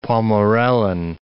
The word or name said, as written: Pommerellen